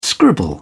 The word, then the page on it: scribble: British English